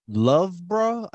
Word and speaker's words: Loughborough: Ricky Carter is from Loughborough, I think is how his relative said it was to be pronounced.